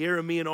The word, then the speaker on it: Aramean: But when they came to the edge of the camp, no one was there, for the Lord had caused the Aramean army, or Aramean is how you say it, Aramean army, to hear the clatter of speeding chariots and the galloping of horses and the sounds of a great army approaching.